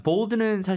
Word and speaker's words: bold: 근데 영어로 발음하실 때는 이름절로 하셔야 돼요. Bold가 아니고 bold.